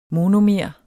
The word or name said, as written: monomer